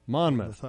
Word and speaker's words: Monmouth: Monmouth.